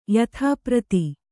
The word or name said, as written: ಯಥಾಪ್ರತಿ